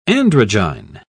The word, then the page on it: androgyne: British English